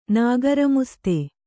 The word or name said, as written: ನಾಗರಮುಸ್ತೆ